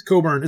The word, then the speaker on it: Cockburn: Oh, Democrat Leslie Cockburn sparked a Twitter... – It's Coburn. It's pronounced Coburn, but you can go ahead and say Cockburn. It's Coburn, but go ahead.